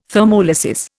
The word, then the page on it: thermolysis: Indian